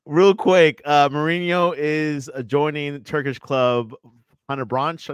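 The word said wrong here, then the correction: Fenerbahçe: I think how you say it. – Yes. – This is Fenerbahce. Sure. Yeah. Fenerbahce.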